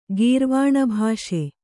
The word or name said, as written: ಗೀರ್ವಾಣಭಾಷೆ